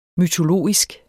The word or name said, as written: mytologisk